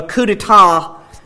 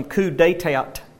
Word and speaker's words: coup d'etat: A coup d'etat is defined, I probably should explain, some of you probably read those words and you, being from Alabama, pronounced them coup d'etat. But that's not it. It's coup d'etat.